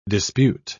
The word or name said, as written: dispute